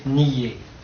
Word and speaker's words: Nied: Et puis il y a aussi deux rivières de Lorraine qui doivent se prononcer nier Non, je ne crois pas. – Qui s'écrivent N-I-E-D, mais qui ne sont en pays de langue française, et les gens du pays disent N-I-E. Mais, alors, ils vous disent même, vous prononcez ça comme pied. Mais je crois qu'on a tout de même tendance, pour N-I-E, à mettre un petit peu plus d'un iode, de se rapprocher de l'I, plus de encore lire.